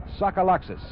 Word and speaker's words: Tsagalakis: Suck Alexis. He spells his name T S A G A L A K I S and pronounces it Suckaloxis.